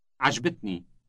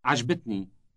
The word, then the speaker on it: عجبتني: عجبتني. عجبتني. – The ni ending is equivalent to the English word me. Say I liked it again. – عجبتني.